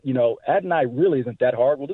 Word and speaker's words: Adonai Mitchell: It's Adonai. So just Adonai. Adonai Mitchell. – So it is three syllables, though. It's not two, Adonai. It's Adonai.